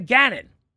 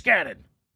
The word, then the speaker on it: Gannon: And Grant was yelling at me yesterday and saying, by the way, it's Jonathan Gannon, like Rich Gannon. I always heard Gannon.